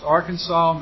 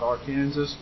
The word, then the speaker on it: Arkansas: That's pronounced Arkansas, not Arkansas.